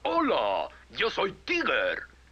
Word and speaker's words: Tigger: Mi nombre es Tigger. T-I-W-G-R. Se pronuncia Tigger.